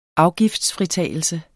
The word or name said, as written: afgiftsfritagelse